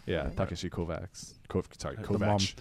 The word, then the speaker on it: Kovacs: It also taught me how to pronounce what I thought was Kovacs. Like it's Kovach? – Something like that.